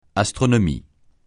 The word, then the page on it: astronomie: francais